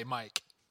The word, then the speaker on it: Mike: I'm going to lose the K in Mike, but there, you have to still pronounce it. So it's M-I apostrophe E. But I will freak out if you don't say Mike.